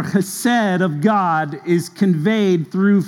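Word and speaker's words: chesed (חסד): When you're pronouncing an H in Hebrew, you kind of got to start it back here in the back of your throat and say chesed, okay?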